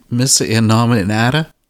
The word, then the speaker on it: Missa Innominata: Missa Innominata? – Yes, perfect.